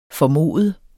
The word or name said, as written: formoder